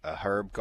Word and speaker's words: herb: You walk in and you walk through a herb garden. – Herb. Yeah, age is silent.